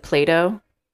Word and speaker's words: Plato: But I definitely pronounce it Plato, and I think most Americans do.